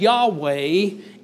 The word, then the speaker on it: Yahweh: All right, so here we have Yahweh is the way this is generally pronounced.